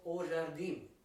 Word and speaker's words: Jardim: is called O Jardim.